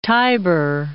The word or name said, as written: Tibur